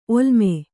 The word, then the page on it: ಒಲ್ಲಮೆ: ollame